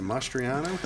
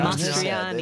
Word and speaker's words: Mastriani: He pronounced the last name Mastriano. – Look at the shine. – Mastriani.